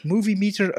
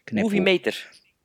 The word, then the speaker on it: Moviemeter: Niet om te muggenziften, maar officieel wordt Movie meter uitgesproken als movie, Engels, en meter in het Nederlands. Wonderlijk, maar waar. – Moviemeter.